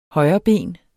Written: højreben

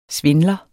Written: svindler

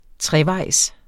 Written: trevejs

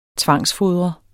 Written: tvangsfodre

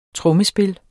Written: trommespil

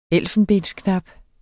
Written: ELFENBENSKNAP

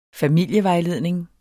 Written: FAMILIEVEJLEDNING

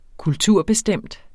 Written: kulturbestemt

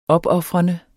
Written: opofrende

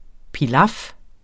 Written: pilaf

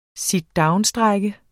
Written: sitdownstrejke

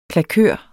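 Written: KLAKØR

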